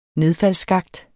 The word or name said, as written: nedfaldsskakt